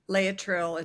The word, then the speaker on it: Laetrile: And you go into the benefits of vitamin C, juicing, Gerson, detox, and I might be pronouncing this wrong, Laetrile? – Laetrile, it's B17, known as B17 also.